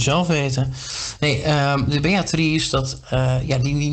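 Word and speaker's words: Beatrijs: Beatrice, Beatrice. In, de, in het middeleeuws Nederlands werd lange i nog uitgesproken als een i.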